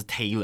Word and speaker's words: Taylor: 但其实他们两个这个名字呢，这个名它的发音还是不一样，一个是 Tyler，一个是 Taylor，对，但是一个是 T A Y L O R，一个是 T Y L E R。 – 哎，这个 L E R 跟 L O R 一定很多人还是会拼错，我觉得啦。 – 其实有时候我知道他叫 Tyler Taylor，有时候你还是会。